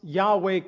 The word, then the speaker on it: Yahweh: And I said, besides, you guys don't even pronounce Yahweh correctly. It doesn't begin with a J, it begins with a Y.